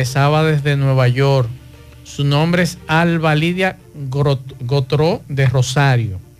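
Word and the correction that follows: Gratereaux: Se escribe Grateró. Así que, eh, digo, es Gratero, perdón, no Gotró, Grateró.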